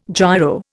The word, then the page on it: giro: Indian